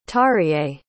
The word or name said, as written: terje